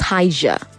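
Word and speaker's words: Higer: I think that's pronounced Higa.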